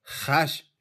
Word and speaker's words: خش: تا کنیزک در وسالش خش شود خش صورت کهنتر تلفظ واژه است که امروز خوش تلفظ میکنیم